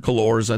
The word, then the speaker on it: Cullors: Cullors, I think it's pronounced.